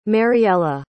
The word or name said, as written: Mariela